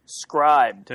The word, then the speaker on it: Scribd: And I'm like, it's spelled like scribed, where they just took away the E, like in the funky cool internet thing to do, right?